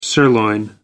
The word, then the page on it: sirloin steak: American English